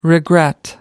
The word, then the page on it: regret: American English